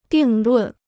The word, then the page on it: 定论: dìng lùn